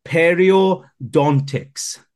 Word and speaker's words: periodontics: Effectively, when someone goes into the industry of, I'm going to say it, okay, periodontics.